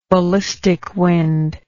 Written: ballistic wind

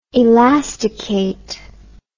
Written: elasticate

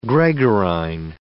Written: gregarine

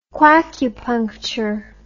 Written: quackupuncture

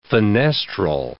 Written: fenestral

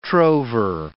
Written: trover

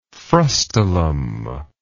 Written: frustulum

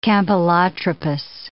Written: campylotropous

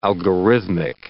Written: algorithmic

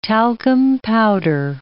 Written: talcum powder

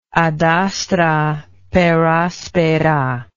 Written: ad astra per aspera

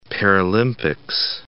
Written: Paralympics

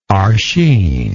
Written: archine

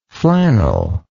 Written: flanneled

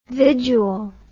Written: vidual

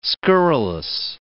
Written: scurrilous

